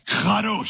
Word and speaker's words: kadosh: I don't know much Hebrew, but I love pronouncing the words that I do know. Kadosh. Doesn't that sound like a fun word to say? You want to say it, don't you? I'll let you. Kadosh. Say it. Kadosh. If the person in front of you is not wiping stuff off the back of their head, you didn't say it right.